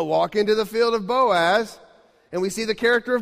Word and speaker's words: Boaz: Remember, guys, you got to say his name like Boaz.